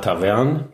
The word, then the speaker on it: Tavern: Nehmen wir nochmal Tavern, der eben schon in der Liste der Ortsnamen mit Betonung auf der zweiten Silbe vorkam.